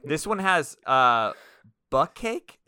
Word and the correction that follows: bukkake: It's B-U-K-K-A-K-E. – Are you trying to say bukkake? – Is that what it is? Bukkake? – Stop playing, bro.